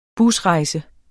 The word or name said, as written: BUSREJSER